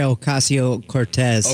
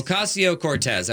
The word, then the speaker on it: Ocasio-Cortez: Alexandria Ocasio-Cortez. – Ocasio-Cortez.